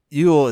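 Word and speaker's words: Ewell: Ill? Oh, sorry. – Yeah, Southwest London. And then he put underneath of it, where... Yule is pronounced you, like the female sheep, then ul, or yule.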